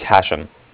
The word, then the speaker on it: Hashem: My name is Hashem. – Hashem? How do you spell that? – That's H-A-S-H-E-M, as in mother at the end.